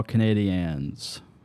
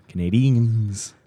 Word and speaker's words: Canadiens: Montreal Canadiens. Number two. – Why are you saying it with an A? – Because that's how you pronounce it in Canada.